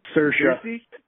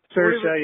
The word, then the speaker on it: Saoirse: Saoirse Ronan? Saoirse? – Saoirse. – Saoirse? – Saoirse, yeah, and I only know that because she was on Conan O'Brien once, and there he, like, clarified how to say her name.